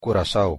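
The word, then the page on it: Curaçao: Papiamento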